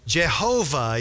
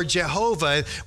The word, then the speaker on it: Jehovah: So it's YHWH, and there's two different ways that we are used to pronouncing YHWH. We add our own consonants in. What's the first way we pronounce it? Yahweh. Yeah, we're used to saying Yahweh. That's the way we often like to pronounce it. But there's another way people pronounce it. You know what it is? Yehovah. Or how do we say it in English? Jehovah. Yeah. So you can pronounce it Yahweh or Jehovah.